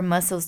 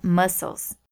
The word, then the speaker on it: muscles: Another one, when you go back to exercising after a long time without exercise, you can expect your muscles to be sore for a few days. And just a pronunciation tip here, I just said muscles. M-U-S-C-L-E-S. And we say muscles.